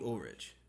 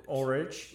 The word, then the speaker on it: Ulrich: Ulrich? Ulrich. – I don't know if he pronounces the CH. – Oh, okay. Well. – I think you pronounce the CH because it's not like Lars Ulrich from Metallica.